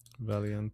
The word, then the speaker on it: Valiente: Did we come to an agreement it's pronounced Vientte? – Valiente. – Valiente. Valiente? – Yeah. Means the brave, by the way. – Oh, okay. – Valiant, yeah. – Yeah, like valiant, kind of, yep.